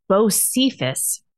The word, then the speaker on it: Bocephus: Okay, the name is pronounced Bocephus, Bocephus, Bocephus.